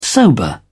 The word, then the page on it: sober: English; British English